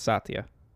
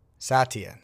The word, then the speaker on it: Satya: Satya. – All right. Not wrong. The H is silent, though, so it's Satya. – Satya.